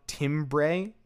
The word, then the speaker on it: timbre: It's spelled timbre, but it's pronounced timbre.